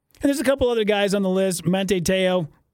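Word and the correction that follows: Manti Te'o: I think I'm saying that right. I always screw that guy's name up. Monte Teo? Monte Teo. Manti. Manti. Ooh, Manti Teo.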